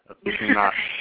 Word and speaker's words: aficionado: How do you say that, honey? Aficionado? – Yeah, aficionado.